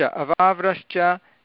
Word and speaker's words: अवाव्रश्च: तत्र वृष् इति कृष्णरिकारः इति वदति वा? अत्र कृष्णरिकारः कुत्र अस्ति? नास्ति खलु. सम्यक्तया वदतु. अवाव्रश्च अवाव्रश्च स.